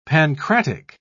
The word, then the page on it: pancratic: British English